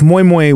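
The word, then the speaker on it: moin moin: And it's moi moi with an N, but you don't really pronounce the N because a lot of Nigerian have silent letters like G's and stuff.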